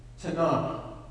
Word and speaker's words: Tanakh: The transliteration is T-A-N-A-K-H, TANAH.